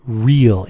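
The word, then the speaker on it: Rhyll: He's the master of the English forest named Ryll. It's R-H-Y-L-L. So I would pronounce that Ryll, right? Wouldn't you pronounce it that way, Ryll? – Yeah.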